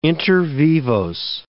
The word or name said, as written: inter vivos